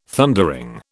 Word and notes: Thundering: British English

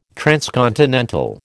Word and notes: transcontinental: American English

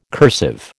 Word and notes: cursive: American English

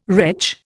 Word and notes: wretch: Indian

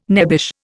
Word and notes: nebbish: Indian

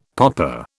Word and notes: poppa: British English

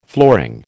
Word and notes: flooring: American English

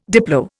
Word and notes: diplo: Indian

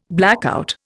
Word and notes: blackout: Indian

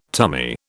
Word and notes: tummy: British English

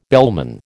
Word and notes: bellman: American English

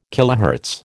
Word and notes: kilohertz: American English

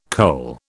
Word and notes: kohl: British English

